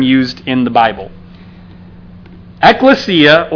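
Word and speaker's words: ekklesia (ἐκκλησία): I have no idea how to, you know, really read that, but that's the Greek word there, that in English you pronounce that ekklesia.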